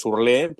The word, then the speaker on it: Hurle: Está españolizado, es Urlé, pero ¿cómo se dice?